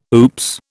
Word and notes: oops: British English

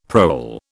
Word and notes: prole: British English

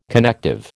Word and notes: connective: American English

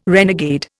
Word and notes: renegade: Indian

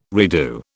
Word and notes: redo: British English

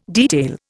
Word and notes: detail: Indian

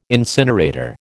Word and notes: incinerator: American English

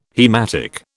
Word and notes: haematic: British English

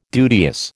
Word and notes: duteous: American English

